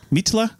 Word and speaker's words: Mitla: It's probably pronounced Mitla. – Mitla? – Mitla. – Yeah. – Because an I sound is in E in Spanish, Mitla.